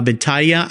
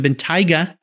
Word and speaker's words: Bentayga: And you just defined that very well uh, to get in that beautiful Bentley uh, Bentayga. I, am saying it could be saying it wrong. It's a Bentayga. Bentayga.